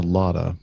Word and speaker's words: Lauda: Lauda motion or Lauda motion? How would you pronounce Lauda. – that? Lauda. – As, as Lauda.